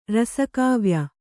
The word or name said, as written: ರಸಕಾವ್ಯ